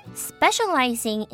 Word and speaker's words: specializing: Specializing in seafood, Nordsee is the third largest fast food chain in Germany, right behind McDonald's and Burger King. 好的，那这句话呢，其实有两个发音重点。第一个字就是 specializing， specializing，注意大家不要念成 specializing 哦，这边要念成 shall， specializing in seafood.